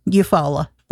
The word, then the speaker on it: Eufaula: Eufaula.